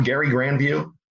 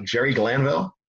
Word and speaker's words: Jerry Glanville: You know why I'm calling him Gary Granville and not Jerry Glanville? – That was how the Three River Stadium PA announcer pronounced his name back when he first started in 87, 86.